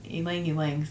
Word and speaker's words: ylang-ylang: And Ylang Ylang. See, you programmed me to say it wrong.